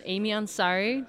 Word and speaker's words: Aimee Ansari: We're here with Aimee Ansari.